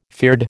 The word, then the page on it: fyrd: American English